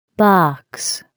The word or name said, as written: box